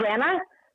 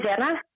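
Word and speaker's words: Xana: I pronounce it Zena. I don't know how it's pronounced in America. – That's correct. – But I say Zena. Mm-hmm. Is that right? – Yes, that's correct. Yes.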